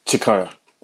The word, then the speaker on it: Cikaya: Cikaya.